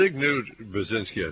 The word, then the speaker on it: Zbigniew Brzezinski: Zbigniew Brzezinski, I think.